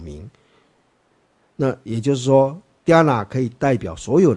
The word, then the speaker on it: Dhyana: Dhyana，那个 H 是没有发音的了啊。